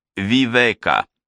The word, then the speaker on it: Вивека: Меня зовут Вивека. С ударением на «е».